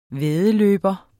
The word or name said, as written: væddeløber